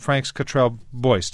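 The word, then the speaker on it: Frank Cottrell Boyce: Now, the screenplay was written by a fellow called Franks Cottrell Boyce. Did I pronounce his name right? – Yeah, that's right, yeah.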